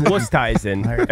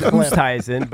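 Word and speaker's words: Oosthuizen: It's Wusthuizen. Everybody says Wusthuizen, but they're actually wrong. – Wusthuizen.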